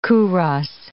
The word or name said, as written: kouros